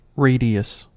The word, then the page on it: radius: American English